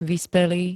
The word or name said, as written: vyspelý